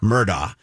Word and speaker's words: Murdaugh: No, it's saying Murdaugh, but it's spelled G-H. So everybody pronounces it wrong. It's actually Murdaugh. – Oh. – But everybody says Murdaugh.